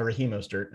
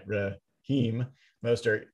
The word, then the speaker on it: Raheem Mostert: Rasheem Mostert, uh, Raheem Mostert, I'm gonna get his name right. Raheem Mostert, uh, two carries for 20 yards um, before he went out with a knee injury.